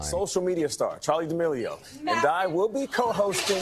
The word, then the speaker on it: Charli D'Amelio: Charli D'Amelio. Am I saying her name right? – Yeah.